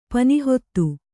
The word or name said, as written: ಪನಿಹೊತ್ತು